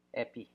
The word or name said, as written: epi